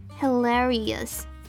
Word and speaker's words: hilarious: 最后一个音节的这个 ous 的 ou 我们要发成 a 这样的弱化母音，所以这个字会念成 hilarious hilarious。